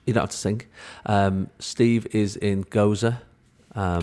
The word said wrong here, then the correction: Gozo: Gozo. – I keep saying it wrong, don't I? Gozo. Gozo.